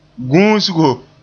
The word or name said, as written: guusgo